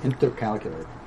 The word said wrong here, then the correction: intercalate: No, it's not calculate. It's not calculate. It's intercalate. Intercalate.